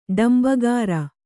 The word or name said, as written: ಡಂಬಗಾರ